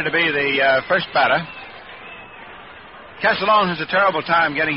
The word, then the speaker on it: Castiglione: It's spelled C-A-S-T-I-G-L-I-O-N-E. It looks like it's an awful tough one. But the way he says the family pronounce it is Castellone, just like O-C-A-S-A-L-O-A-N, Castellone.